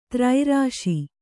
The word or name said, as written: ತ್ರೈರಾಶಿ